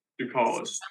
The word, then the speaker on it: Tsoukalas: I'm sorry, but it's Tsoukalas. – Tsoukalas. – Silent T.